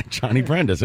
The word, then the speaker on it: Johnny Brenda's: They're playing a place called Johnny Brenda's in Philly, and I think that's how you pronounce it. – Yeah, Johnny Brenda's. That was pretty good. – Yeah, it was phonetic.